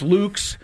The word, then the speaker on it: Lukes: It's spelled L-U-K-E-S, so it looks like it's Lukes.